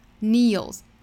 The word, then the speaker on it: kneels: 这个 kneel 尾部也是 E-E-L-S 结尾的，那所以发音跟前面这一句的尾部的 feel 是一样的， Feels, kneels. 所以这也是押了韵的。